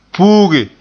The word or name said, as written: puuge